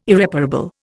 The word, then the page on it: Irreparable: Indian